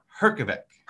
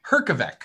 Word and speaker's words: Herjavec: No. Herjavec. – Herjavec. – Yes. What there's Robert, no J. It, there's a J in the name. It just, you just don't pronounce it.